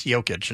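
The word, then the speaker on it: Jokić: I wish they'd stop calling him the Joker, because he doesn't joke around a lot, and it's a soft J. It's Jokic. It's not... He's not the Joker, but that would be more consistent.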